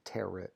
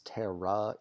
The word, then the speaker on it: tarot: Like, the fucking T at the end just really messes with me. I want to keep saying tarot, but it's terra, even though it's an OT.